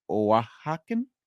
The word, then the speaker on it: Oaxacan: It was a, let me see if I learned from Josh correctly, a Oaxacan? O-A-X-A-C-A-N, Oaxacan, I believe he said it.